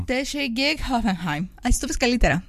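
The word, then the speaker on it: Hoffenheim: Χόφενχάιμ. Δεν χρειάζεται να βάλεις προφορά, με τη Χόφενχάιμ παίζει, είναι απλά. – Α, Χόφενχάιμ.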